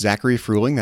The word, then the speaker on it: Zachary Fruhling: You can also find me on Twitter, and my handle there is just Zachary Fruhling. That's my name. Z-A-C-H-A-R-Y-F-R-U-H-L-I-N-G is how you spell that.